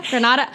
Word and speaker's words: Granada: Granda. Wait, hold on. We talked to our consultant. – We used our one phone call. – How do you pronounce it? – Granada. Anyways, Granada.